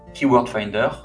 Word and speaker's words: KW Finder: Et ensuite, tu vas pouvoir utiliser une solution payante de recherche de mots-clés comme Keyword Finder. Donc, ça s'écrit KW Finder. Donc, KW, c'est l'acronyme de Keyword.